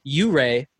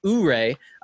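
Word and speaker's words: Ouray: My username is pronounced Hip Hip U-Ray, not Ooray.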